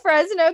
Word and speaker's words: Fresno: Frenzo, California. – Fresno. Friendzone, California. – You know, as I said that, I was like, ooh, that wasn't right. That was not right. Fresno, California.